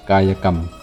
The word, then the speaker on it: กายกรรม: กายกรรม